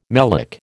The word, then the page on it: melic: American English